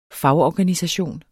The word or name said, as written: fagorganisation